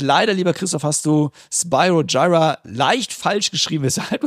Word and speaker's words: Spyro Gyra: Spyro Gyra wird geschrieben S-P-Y-R-O, Spyro, und dann kommt ein neues Wort, G-Y-R-A, Gyra, Spyro Gyra, ausgesprochen.